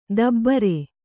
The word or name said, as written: ದಬ್ಬರೆ